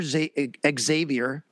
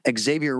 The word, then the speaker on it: Xavier: That obviously could be problematic, but that's where Xavier Rhodes comes in. That's how you say his name. I asked him. You do say the X. Xavier Rhodes might come in.